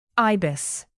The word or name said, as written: ibis